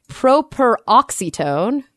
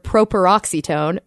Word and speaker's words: properoxytone: Properoxytone. Yeah, properoxytone. And that word, the definition of the word properoxytone means that you stress it on the antepenultimate syllable. Mm. So the third to last syllable.